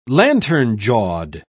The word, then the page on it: lantern-jawed: British English